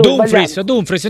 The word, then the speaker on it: Dumfries: Ma questo Dumfries, come si pronuncia? Non lo so, adesso Dumfries, non ti preoccupare, Dumfries.